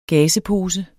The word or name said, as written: gazepose